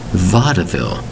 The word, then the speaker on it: vaudeville: Two syllables into three. Vaudeville. Spelled V A U D E V I L L E. Vaudeville. And you say to yourself, it looks like two syllables when you spell out the word. V A U D E V I L L E but there's three syllables in there.